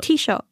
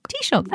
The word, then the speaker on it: Taoiseach: I'm going to try and pronounce this. Taoiseach. – It's a Taoiseach. – Taoiseach. – Taoiseach. That's right. That's how to say it, isn't it? – Yeah.